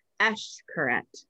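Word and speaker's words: Xcaret: So it's like I would always say Eshkaret, but it's actually Eshkaret. I don't know. I'm probably still not saying it right, but it's like instead of an X, I guess.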